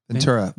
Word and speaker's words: Ventura: Which you pronounce as Ventura. – Ventura? – Ventura. I mean, Ventura. How else? – Ventura. Ventura. Ventura. – Ventura. – Yeah. – Like tuna.